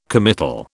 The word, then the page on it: committal: British English